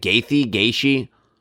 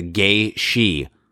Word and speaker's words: Gaethje: Gaethje.